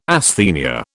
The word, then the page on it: asthenia: British English